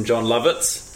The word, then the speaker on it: Jon Lovitz: The second thing you get to see from John Lovitz is I like that you looked to me for approval.